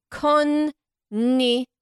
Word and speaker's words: konnichiwa: There's an extra bit in there. It's kon, and then there's an N all by itself. N. And then ichiwa. – Okay, say it like, again. Say it. Man. – It's like konnichiwa. – Oh, konnichiwa. – It's important to have the two N's.